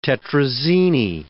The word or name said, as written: tetrazzini